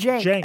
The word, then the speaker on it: Cenk: Cenk.